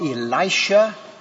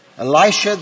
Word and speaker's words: Elisha: In our first lesson for today, through the ministry of Elisha, or some pronounce it Elisha, the Israelite prophet, Naaman, the Syrian general, is healed of leprosy.